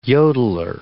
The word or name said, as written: yodeler